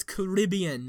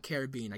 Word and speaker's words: Caribbean: Also, it's Caribbean, not Caribbean. I keep saying Caribbean, but it's Caribbean, Pirates of the Caribbean. – But that sounds stupid. – But that's how it's pronounced.